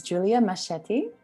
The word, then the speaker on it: Julia Mascetti: Her name is Julia Machetti.